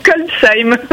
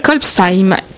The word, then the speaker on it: Kolbsheim: Et qui s'appelle ce village Kolbeich. Ah non, voilà, j'ai raté mon coup. Je ne vais pas le refaire. – Kolbsheim. – Kolbsheim, qui s'écrit K-O-L-B-S-H-E-I-M, qui se trouve en Alsace, et qu'on va inviter nos amis auditeurs à aller aussi découvrir.